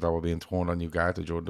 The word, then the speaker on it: Ugarte: Ugarte.